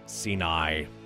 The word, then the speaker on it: Siinai: So from 2016's My Best Human Face, this will be Moonface along with Sinai. That's spelled S-I-I-N-A-I, Sinai.